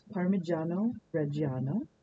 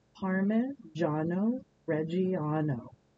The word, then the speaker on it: Parmigiano: And that name is Parmigiano Reggiano. You don't have to be able to speak Italian to ask for it at the cheese counter. All you have to ask for is Parmigiano Reggiano.